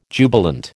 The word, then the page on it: jubilant: American English